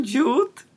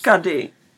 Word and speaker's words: Cudi: Každopádně uh, je tam nový borec, který pracuje na baru a jeho jméno se píše C-U-D-I ale vyslovuje se to Jude, jakože to je blbost. kady,